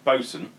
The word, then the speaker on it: boatswain: You know Boatswain? Bosun.